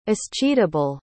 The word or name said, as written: escheatable